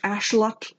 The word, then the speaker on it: axolotl: Because X is actually a sh sound. Mm-hmm. So it's actually ashlot. – Yeah. – And that's how you pronounce that.